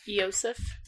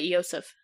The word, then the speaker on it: Iosif: How do you think you pronounce this word, this name? I-O-S-I-F. – I-O-S-I-F. – I-O-S-I-F. – Yosef. – Yosef? – Yosef?